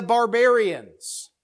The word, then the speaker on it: barbarian: So if you cut off the ians at the end of it, for some reason we have B-A-R, and we pronounce those two B-A-Rs differently. Barbarian.